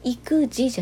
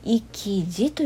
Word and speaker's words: 意気地: この時は育児じゃなくて生児と読むそうですよ